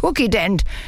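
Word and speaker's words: Kukident: Zuzident, 3-Phasen-Reiniger. – Oh, Frieda, das schreibt man mit K, das heißt Cookie-Dent.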